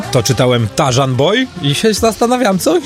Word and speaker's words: Tarzan: Tarzan, że niby RZ, ale nie czyta się jako Ży, tylko Rzy.